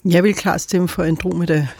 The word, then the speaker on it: Andromeda: Og så ved jeg, at vi kalder den også for, og jeg ved faktisk ikke, hvordan man udtaler det, er det Andromeda-galaksen, eller er det Andromeda? – Ja, det er det sidste.